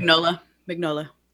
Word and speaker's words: Mignola: Mignola.